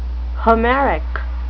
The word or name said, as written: homeric